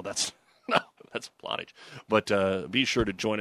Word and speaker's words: Plottage: And for those of you that are new to the areas, it looks like Platt Deutsch, but it's actually pronounced Plottage.